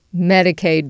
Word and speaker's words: Medicaid: Medicaid benefits. Am I saying that right? – Yeah.